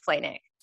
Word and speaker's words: Flajnik: So on the show, you are obviously the most memorable person from your season, which was Ben Flanick. That's how you pronounce it, right? – Yes. Mm-hmm. – Spelled like Flajnik, so that's a little difficult.